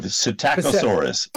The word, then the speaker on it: Psittacosaurus: You can say it. Psittacosaurus. – Psittacosaurus. – It's a silent P.